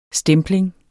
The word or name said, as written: stempling